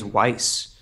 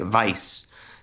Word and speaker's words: Weiss: Her name is Weiss, and people are like, "Well, the correct German pronunciation would be like Vice." So the creator Monty Ohm was like, "Okay, but this is how we're going to pronounce it because it's her name.